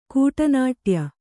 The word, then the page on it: ಕೂಟನಾಟ್ಯ: kannada